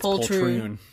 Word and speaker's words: poltroon: you stupid poltron," or I probably should have looked up the pronunciation. – I think it's poltroon. – Yeah. "Unhand me, you stupid poltroon."